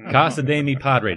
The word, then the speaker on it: Casa de mi Padre: And this week, we're going to queue up the 2012 film, An Ode to Will Ferrell's Rear End, Casa de mi Padre.